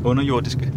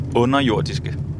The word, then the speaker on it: underjordiske: Can you teach me how the right way to say it? The underearthlings. – Underjordiske. – One more time. – Underjordiske. – Underjordiske. – Yeah. Underjordiske.